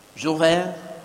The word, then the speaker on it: Jules Verne: Jules Verne? – Jules Verne.